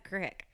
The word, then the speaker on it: creek: And what I love is that you'll see signs all over the place that says this creek and this creek and this creek. And Susan goes, that's not a creek. That's a crick. – That's right. – But it's spelled creek.